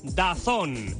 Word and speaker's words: DAZN: DAZN, pero se pronuncia DAZN.